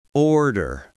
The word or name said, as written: order